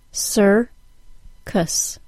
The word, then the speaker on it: circus: Circus. Sir-cus. Circus.